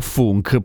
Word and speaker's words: Funk: si pronuncia Funk perché non è inglese ma è polacco.